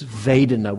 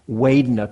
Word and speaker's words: vedana: If you're interested in the Pali language, it's vedana with a V. Sometimes you'll hear it pronounced vedana because the Burmese, with a Burmese accent, Pali is, the V's are pronounced with W's, but in standardized international, it's a V, so V or W, vedana or vedana.